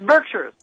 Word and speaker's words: Berkshires: Is that how you pronounce it? – It's Berkshires. – Berkshires. It is Berkshires. Okay. – Yes, it is.